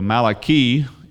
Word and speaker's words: Malachi: So Malachi is the way to correctly pronounce it, or East Texas correctly pronounce it, you know.